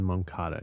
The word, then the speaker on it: Moncada: And how do you pronounce this? Moncada? – Yoan Moncada.